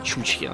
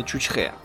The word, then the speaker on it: Чучхе: У нас многие почему-то думают, что Чучхе, и, и произносится, кстати, неправильно, Чучхе, на самом деле именно Чучхе.